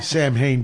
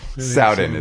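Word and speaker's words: Samhain: Sam Hain didn't have, which is pronounced wrong, people. – Soudan is that's how you pronounce it.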